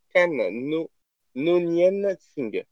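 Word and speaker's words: Khan Noonien Singh: Alors son nom je vais en chier pour le prononcer, c'est Khan Nonien Singh, c'est bon ? – Ouais, je t'en ai bien sorti.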